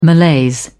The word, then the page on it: malaise: British English